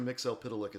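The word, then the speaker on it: Mxyzptlk: I'm telling you that Mr. Mixel Pitalik is how you say it based on that record.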